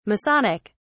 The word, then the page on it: masonic: British English